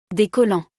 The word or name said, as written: Des collants